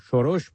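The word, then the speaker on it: Soros: مؤسسه جامعه باز توسط جورج سوروس یا شوروش به تلفظ مجاری آن،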